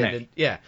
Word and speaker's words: DeNA: I might start with if you know how to pronounce this, DNA, is DENA, this company, yeah, DNA.